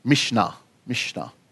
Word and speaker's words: Mishnah: Mishnah.